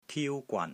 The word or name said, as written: TIU1 GWAN3